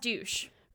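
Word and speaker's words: Deutch: It's Zoe. Pronounced douche. – write in, let us know. – Douche. It's pronounced douche, Sam.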